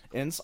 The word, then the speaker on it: Ince: I think it's just, I like NC. Is that how you say it? I-N-C-E? Is that how you say it? Ince.